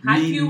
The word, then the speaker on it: Haikyuu: Haikyuu.